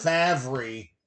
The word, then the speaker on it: Favre: Favre. It's spelled Favre, but it's pronounced Fav.